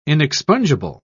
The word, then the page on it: inexpungible: British English